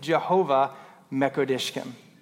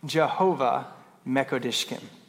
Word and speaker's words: Jehovah Mekoddishkem: Can you guys say that with me? Jehovah Mekoddishkem. Maybe one more time. Jehovah Mekoddishkem. Anybody know what that means? Good job. I heard at least a couple of people say it right.